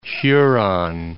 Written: Huron, Lake

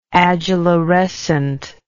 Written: adularescence

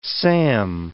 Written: Sam

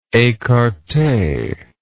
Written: ecarte

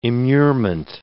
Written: immurement

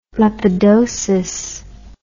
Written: lepidosis

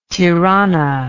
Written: tirane

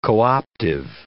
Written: co-optive

